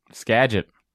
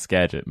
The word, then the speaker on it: Skagit: Skagit. – He put that in parentheses right next to it, pronounced Skagit, and I'm glad that you also could pronounce it correctly.